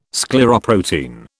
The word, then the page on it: scleroprotein: British English